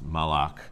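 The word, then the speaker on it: malak (מלאך): So in some circumstances, and in Hebrew, the word is, we'll just say MLK, it's pronounced malak.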